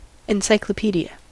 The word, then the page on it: encyclopaedia: American English